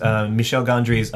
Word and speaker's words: Michel Gondry: My main takeaway from it, again, because I haven't seen it in a while, is that I hate mispronouncing things, Michel Gondry's directing style. He's very... – You said it perfectly.